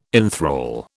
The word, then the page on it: inthrall: British English